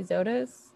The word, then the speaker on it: Zotos: Is it did you say Zotos? – Z-O-T-O-S. – Okay. – Or V-O-T-O-Z, really, I guess.